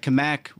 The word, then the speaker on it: Cammack: Did I say that right? – You did say right. Kamek Retirement Kamek. – Group. Yep. – Kamek. – Absolutely.